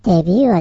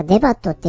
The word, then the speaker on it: debut: デビューはデビューなんだね。でもスペルは D-E-B-U-T。なんかデバットって読んでしまいそう。デビューか。